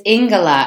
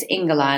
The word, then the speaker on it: Ingela: It is Ingela, and I always tell people it rhymes with sing, and I'm also a singer. So if you just think of sing, it's Ingela, and it's a Norwegian name. So I know a lot of people see it, and they want to pronounce it like Angela.